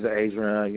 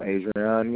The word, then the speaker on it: Adrione: This is Adrian Young. I really need to speak with Ms. Amanda. – You said your first name was Adrian? – Yes, ma'am. Adrian Young. – Spell that first name. – A-D-R-I-O-N-E.